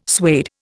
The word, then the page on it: suede: Indian